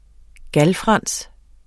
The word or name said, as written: GALFRANSENS